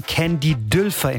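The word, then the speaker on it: Candy Dulfer: Und für die Aufnahmen, da holte er sich aus den Niederlanden die Saxophonistin Candy Dülfer ins Studio, wie sie in ihrer Heimat ausgesprochen wird.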